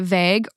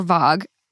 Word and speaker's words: Vogue: Is it vague or vogue? – Vogue. I love it.